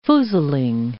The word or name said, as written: foozling